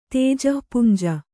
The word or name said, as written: ತೇಜಪುಂಜ